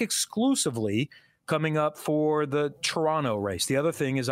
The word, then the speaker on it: Toronto: No T, no second T, Toronto. I'm trying. I'll fail many times. And because it's Peacock, to make everyone feel more comfortable, I'll be in the booth for the Toronto weekend.